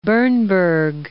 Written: Bernburg

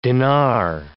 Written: dinar

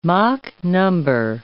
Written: Mach number

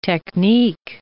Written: technique

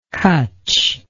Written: cutch

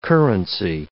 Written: currency

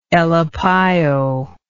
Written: elepaio

